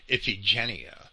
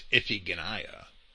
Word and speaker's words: Iphigenia: Sometimes it's pronounced or mispronounced Iphigenia, but it's Iphigenia Agamemnon sacrificed Iphigenia,